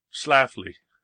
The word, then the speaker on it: Schlafly: I can say her name right usually. Schlafly.